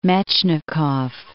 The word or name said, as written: Metchnikoff